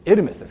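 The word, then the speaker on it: Hermès: ヘルメスというのは皆さんよくご存知のブランドのね、ヘルメスってエルメスですけどね、あれの元はこれですから、別に聖書から来てるわけじゃないですね。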